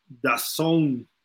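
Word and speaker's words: DAZN: ya vi lo de Dazón, ¿no? Es como se pronuncia, nos dijo Jürgen, y por ahí en general es lo que dicen.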